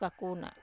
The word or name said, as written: kakuna